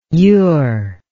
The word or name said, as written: youre